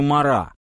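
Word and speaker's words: Marat: До революции Оноре Габриэль Викторович Рикети, граф де Мирабо, вряд ли подал бы руку провинциальному врачу Жану Полю Жан-Батистовичу Мара. Нам он известен как Марат. В нормативном французском произношении последняя буква большинства слов проглатывается.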